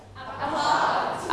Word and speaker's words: pot: a pot a pot okay don't forget to pronounce the final sound pot that's right a pot นะคะก็คือสิ่งที่เป็นยังไงเอ่ย containers ก็คือสิ่งที่บรรจุของได้นะคะโอเคต่อไป a kitchen machine เป็นเครื่องใช้ในครัวนี่แหละ That mix and crushes food and drink into smooth liquid.